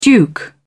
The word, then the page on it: duke: British English